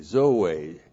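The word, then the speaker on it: Zoe (ζωή): Z, long O, long E. Zoe.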